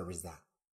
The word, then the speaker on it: RZA: Se escribe RZA y se pronuncia Riza.